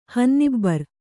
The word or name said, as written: ಹನ್ನಿಬ್ಬರು